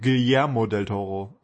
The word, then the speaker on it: Guillermo del Toro: Und dann momentan eben Trollhunters, eine sehr schöne Serie von, ich habe es vorhin falsch gesagt, von Guillermo del Toro.